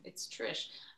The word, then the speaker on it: Trish: Actually, often when I say my name, people, if I say Trish, they say Chris. And if I say Trisha, they say Michelle. So I just did Trish Like Stratus. That's the name. It's Trish.